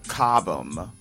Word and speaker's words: Cobham: article up by Tara Cobham. I think that would be pronounced Cobham, C-O-B-H-A-M. You could even say Cobham if need be.